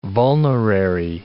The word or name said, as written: vulnerary